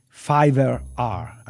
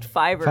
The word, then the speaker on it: Fiverr: In preparing this episode, I discovered there is a thing called Fiverr R. I don't know how to pronounce it. Do you know that? – Yeah, I think it's called just Fiverr. – Fiverr, okay.